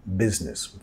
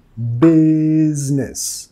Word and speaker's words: business: Let's get down to business. Phrasal verb ahí, get down. De la palabra business, business. Queremos enfatizar la primera sílaba y la primera S en business. Vamos a pronunciar con sonido Z.